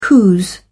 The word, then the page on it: whose: British English